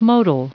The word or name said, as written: motile